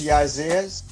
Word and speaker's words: Jazzez: Jazzez, am I saying it right? I feel like I'm not saying it right. – It's pronounced Jazzez. Jazzez X Jones.